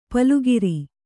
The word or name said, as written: ಪಲುಗಿರಿ